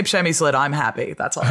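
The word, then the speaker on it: Přemyslid: Przemyslid. So it's a sound that we have in Czech that other people don't have, R with the ha Czech.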